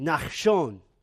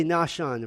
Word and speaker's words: Nahshon: In Hebrew, you would say it Nachshon. We would say Nahshon if we just pronounce it in English.